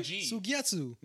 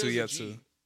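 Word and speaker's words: Suigetsu: Suyetsu. – No, it's Suyetsu. – There is a G, but I didn't know if it was.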